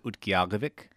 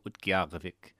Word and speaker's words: Utqiagvik: But most people pronounce it Utqiagvik. – Utqiagvik. – Utqiagvik. And it's a k sound. So Utqiagvik.